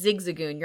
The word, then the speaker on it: Zigzagoon: It's Zigzagoon.